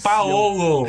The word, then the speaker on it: Paolo: E eu queria não, não é Paulo, é Paulo, é Paolo.